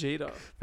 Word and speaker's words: Vegeta: No, it's very Vegeta. Like Vegeta. Like if Vegeta, yeah. – Vegeta. Like, or something like Japanese. Like you'd sound out the syllables. The sounds.